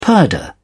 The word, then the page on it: purdah: British English